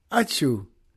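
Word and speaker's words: ačiū: It's uh, spelled like uh, a sneeze, like achoo. And I said, oh, so achoo? And she smiled and said, achoo.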